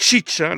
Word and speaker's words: Xixa: Alors tout de suite, autre chose, mais enfin on reste dans la même région, le désert de l'Arizona et Tucson, c'est Chicha, là ça s'écrit XIXA,